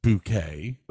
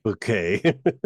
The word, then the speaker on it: Bucket: Whose name she insists is pronounced Bouquet. – Bouquet.